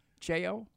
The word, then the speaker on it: Cheo: How you pronounce his name? C-H-E-O? Cheo? – Cheo, yeah.